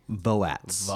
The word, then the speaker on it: Voatz: And it leveraged, I believe, a company called Votes, V-O-A-T-Z. – Voats.